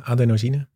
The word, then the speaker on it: adenosine: Kijk, melatonine hebben we allemaal wel eens van gehoord, maar we hebben ook adenosine, zeg ik het goed? – Ja, adenosine.